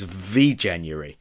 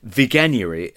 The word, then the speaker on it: Veganuary: I thought it was V-January, but the G in Veganuary is hard, apparently. So I guess that's pretty obvious in retrospect. So Veganuary, I read in the Mail that one in eight 18 to 24-year-olds are doing Veganuary, which means going vegan for January.